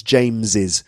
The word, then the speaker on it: James's: You can do J-A-M-E-S apostrophe S room. You can do it like that too. How do you say that? Well, it's, as I've said, it's James's. And that's with apostrophe S or just apostrophe. They're both pronounced James's.